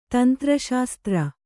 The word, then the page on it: ತಂತ್ರಶಾಸ್ತ್ರ: tantra śastra